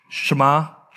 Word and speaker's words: Shema: How do you say that word? Shema. Say it correctly? Shema.